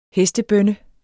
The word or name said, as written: hestebønne